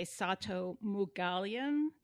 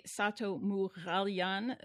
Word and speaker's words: Sato Moughalian: So I pronounce my name in the United States. I say Sato Moughalian. It's really Sato Moughalian. There's a R sound in Armenian that doesn't exist in English.